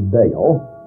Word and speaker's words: Baal: One, the Hebrew word Baal, which in English we spell B-A-A-L and generally pronounce Baal, and the other was the Hebrew word Adonai.